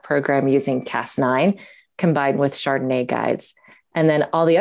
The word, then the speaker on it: chRDNA: So we wrote that out as a little acronym, chRDNA, which we pronounce Chardonnay.